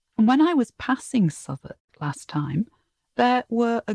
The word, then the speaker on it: Southwark: Southwark is spelt S-O-U-T-H. W-A-R-K. So you might think that this place name would be pronounced Southwark. That seems logical. But no, actually this place name and this stop on the Jubilee line is called Southwark.